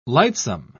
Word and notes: lightsome: British English

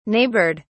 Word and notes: neighbored: British English

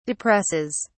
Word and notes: depresses: British English

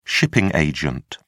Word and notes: shipping agent: British English

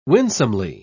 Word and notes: winsomely: British English

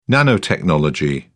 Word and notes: nanotechnology: British English

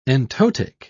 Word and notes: entotic: British English

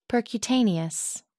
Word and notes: percutaneous: American English

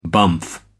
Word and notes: bumf: American English

